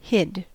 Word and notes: hid: American English

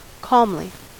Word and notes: calmly: American English

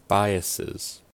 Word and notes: biases: American English

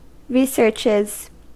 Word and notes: researches: American English

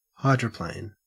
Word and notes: hydroplane: Australian English

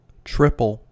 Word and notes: triple: American English

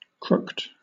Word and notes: crooked: British English

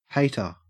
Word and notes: hater: Australian English

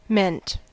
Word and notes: meant: American English

